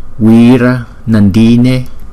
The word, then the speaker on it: Wira Nandine: Rồi, tinh tấn hỷ Phật thì chúng ta phát âm là Wira Nandine, Wira Nandine, Wira Nandine, Wira Nandine. Chữ Y có dấu gạch ngang, chúng ta đọc là hai nhịp.